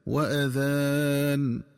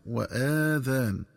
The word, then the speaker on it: وأذان: ينبغي التنبه للنطق الصحيح لكلمة وأذان، فتنطق بهمزة مفتوحة دون مدة هكذا: وأذان، بمعنى وإعلام. ومن الخطأ قراءتها بفتح الهمزة وإشباع فتحتها حتى يتولد منها ألف فتصير وآذان، والآذان جمع أذن.